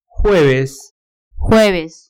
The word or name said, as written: jueves